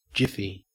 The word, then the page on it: jiffy: Australian English